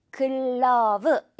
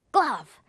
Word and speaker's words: glove: 자, 먼저 첫 번째 단어, 장갑이라는 단어인데 이거 우리 한국말로 하면요, 글러브 이렇게 세 글자처럼 들려요. 글러브. 자, 그런데 우리 물총 그렇게 빵빵빵 세 번이나 쏠 수가 없어요. 딱 한 번에 발음을 하려면요, 이 빨간색 그 모든 걸 한 번에 glove 이렇게 발음이 됩니다. Glove. Glove. 이게 아니라 여러분, glove. 어떤 차이인지 느껴지시죠? Glove. 마지막 이 v 부분은 굉장히 살짝 해달라는 겁니다.